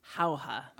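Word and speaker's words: Jauja: Our first comes from Michael Locker, and he is going to recommend the film, is it pronounced Howha? – Howha. – All right, I got it right.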